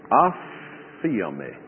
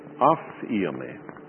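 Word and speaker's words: Aphiomi (ἀφίημι): Aphiomi. Aphiomi. Let's just transliterate it this way. And that's a long E. Aphiomi.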